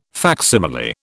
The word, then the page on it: facsimile: British English